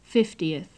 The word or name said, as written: fiftieth